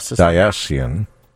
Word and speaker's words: diocesan: Bishop Roger Foyles of Covington, Kentucky, issued an apology Friday for a January 19th diocesan statement. Did I say that right? – Diocesan.